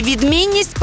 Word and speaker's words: відмінність: Наголос на слові «відмінність» падає на другий склад.